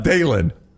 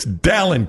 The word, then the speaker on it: Dalen: If we run it back sometimes, my name is pronounced like Alan, not Dalen. I said his name wrong the entire interview. It's Dalen Cuff. Alan with a D, Dalen Cuff, not Dalen.